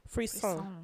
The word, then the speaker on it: frisson: Frisson. – Frisson. Okay. That's really how you say it, frisson. – Okay, for sure. – It's spelled F-R-I-S-S-O-N. I almost said F-R-E-E.